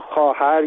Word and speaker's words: خواهر: به عنوان مثال ما خواهر یا خواستن رو با واو می‌نویسیم، در حالی که این صدا آ هست. علتش اینه که در گذشته خواهر یا خواستن تلفظ می‌شده. در به اصطلاح زبان کهن فارسی، در زبان میانه فارسی یا پهلوی، این خوا خوی ما زیاد داشتیم.